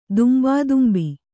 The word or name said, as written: ದುಂಬಾದುಂಬಿ